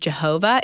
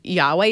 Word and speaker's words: Yahweh: So the first one we did was on Yahweh, the Hebrew name of God, which sometimes you might hear this name pronounced Jehovah. It's technically should be pronounced more Yahweh is closer.